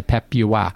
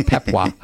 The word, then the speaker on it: Papua: So you don't say Papua. – Yeah. – No, it's Papua.